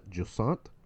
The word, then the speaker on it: Jussant: Next up, we have Hogwarts Legacy, what I'm going to assume is pronounced Jussant. – That is correct.